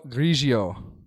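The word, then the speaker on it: Grillo: 53, Emiliano Grigio. That's how you're supposed to pronounce his last name, by the way, as confirmed by our friend Tomas.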